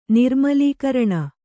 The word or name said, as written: ನಿರ್ಮಲೀಕರಣ